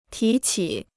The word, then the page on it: 提起: tí qǐ